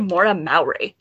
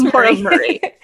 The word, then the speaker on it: Maura Murray: It is no, it's Maura Murray. – It's Murray. I always want to say Mowry. – Why do you want to say Mowry? It's spelt Murray.